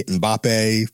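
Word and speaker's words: Mbappé: He also had a record with Future recently, Mbappe.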